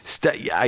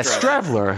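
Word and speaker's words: Streveler: I'm seeing Strevler. Chris. – Yeah, Strevler. Who wouldn't know that? Not Stevler. – The R is silent. – It's not Stevler.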